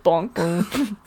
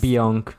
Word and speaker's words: Bonk: Heard of Bonk? – It's actually pronounced Bionk.